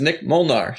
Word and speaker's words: Nik Molnar: My name is Nik Molnar, said the exact same way.